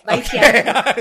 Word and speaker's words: Vaitiare: But yes, Vaitiare.